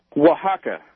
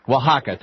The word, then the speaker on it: Oaxaca: Is it pronounced Oaxaca? – Oaxaca. – Oaxaca, thank you. You're right. Oaxaca.